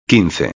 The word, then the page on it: 15: espanhol